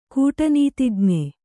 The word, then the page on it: ಕೂಟನೀತಿಜ್ಞೆ: kūṭa nītij'ne